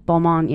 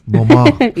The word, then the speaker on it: Balmain: I'm a big fan of Balmain. Is that how you say it? Yeah. – Balmain. – Yeah. Okay. – Balmain.